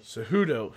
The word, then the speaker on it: Cejudo: You got Henry Cejudo. Is that how you say his name? – Cejudo.